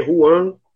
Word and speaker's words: Juan: Então, meu nome é Juan Jorge Pérez, né? se escreve Juan, se lê Juan.